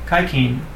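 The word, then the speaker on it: haijin: Kaikin, pronounced, I don't know the tones, but something like hygiene.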